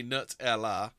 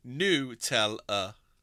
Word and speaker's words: Nutella: While it seems common to say Nutella, since it's made from hazelnuts, it's actually Nutella.